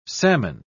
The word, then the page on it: psammon: British English